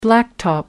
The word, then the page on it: blacktop: American English